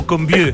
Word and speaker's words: Combeau: ¿O Combeau? – Exactamente, Combeau, Rodrigo. – Combeau, excelente. Es como un combo, pero con el acento en otra parte.